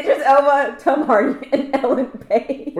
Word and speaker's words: Idris Elba: Idris Elba? – I think so, yeah. – Say it again. – Idris Elba.